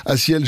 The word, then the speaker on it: A6EL: Je vais vous appeler Aciel si vous le connaissez pas encore et si vous le cherchez, parce que ça s'écrit un peu spécialement A6EL, à ciel, pour cette chanson qui s'appelle excusez-moi, Soleil.